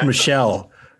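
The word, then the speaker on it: Michel: All right, so Michael Pereira, right? Is that how you say? – Yeah, no, it's pronounced Michelle. Like Michelle Pereira.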